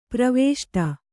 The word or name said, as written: ಪ್ರವೇಷ್ಟ